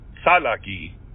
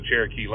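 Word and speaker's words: Tsalagi: And I think it actually, in their language, it's pronounced Salaki, but we Europeanized it and made it Cherokee. But, yeah, I actually want to learn the Cherokee language.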